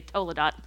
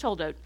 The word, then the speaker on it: toldot: So a toldot, I had to look up how to pronounce that, because I want to say toledot. It's not toledot, it's toldot.